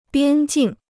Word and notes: 边境: biān jìng

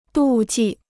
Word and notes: 妒忌: dù jì

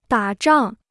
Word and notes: 打仗: dǎ zhàng

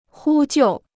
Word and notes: 呼救: hū jiù

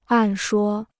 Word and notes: 按说: àn shuō

